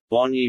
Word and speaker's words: Bonyi: Or, to be correct, Bonny Mountains the natives always pronounced it so.